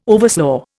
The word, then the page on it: overslaugh: Indian